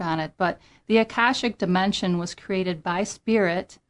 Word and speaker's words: Akashic: So first of all, tell everybody, what are the Akashic Records? And am I saying it right? – Yes.